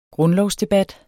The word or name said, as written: grundlovsdebat